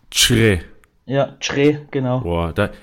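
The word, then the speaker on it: Kyereh: und ich ärgere mich, weil ich es vorhin selber falsch ausgesprochen habe, eigentlich sagt man Chre. Also oh. sein Name wird Schre ausgesprochen. So hat er es uns auf jeden Fall erklärt.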